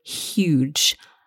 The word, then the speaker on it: huge: The thing to watch out for here is that we must pronounce the H. So huge, not huge.